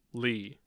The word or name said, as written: Lee